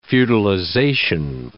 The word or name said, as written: feudalization